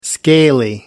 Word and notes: scaly: American English

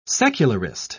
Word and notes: secularist: British English

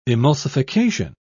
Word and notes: emulsification: British English

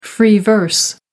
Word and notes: free verse: American English

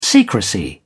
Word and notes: secrecy: British English

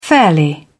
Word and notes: fairly: British English

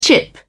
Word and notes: chip in: British English

